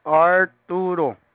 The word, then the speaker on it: Arturo: Arturo. – Arturo. Can you spell it? – Arturo. A-R-T-U-R-O. Arturo. – A-R-T-U-R-O.